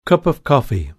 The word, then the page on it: cup of coffee: British English